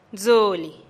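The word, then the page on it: dzulih: Teotitlán del Valle Zapotec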